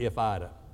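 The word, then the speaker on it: ifidi: It's spelled I-F-I-D-I, I-F-I-D-I. You pronounce it, if I'd have.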